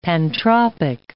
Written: pantropic

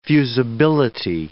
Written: fusibility

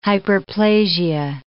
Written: hyperplasia

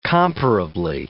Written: comparably

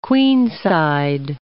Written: queenside

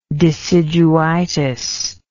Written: deciduitis

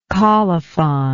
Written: colophonic

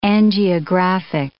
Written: angiographic

